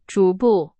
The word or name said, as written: 逐步